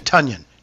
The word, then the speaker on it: Tonyan: That touchdown pass from Aaron Rodgers to Robert Tonyan, and by the way, that's how he's pronouncing his last name now. – Tonyan. Correct. – Tonyan. Did you guys see that? – Correct. – Yeah. – Yeah, I did. – Think of Funyan, yeah.